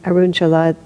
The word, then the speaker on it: Arunachala: And so he wandered here and there for a while, and finally ended up in, how do you pronounce it, Arunachala? Yeah, he started in Arunachala.